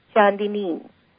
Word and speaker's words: Chandini: C-H-A-N-D-I-N-I, Shandini.